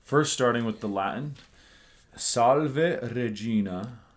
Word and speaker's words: salve: The ending, you want to avoid diphthongs as usual, so salve, you want to avoid that and just pronounce it as salve.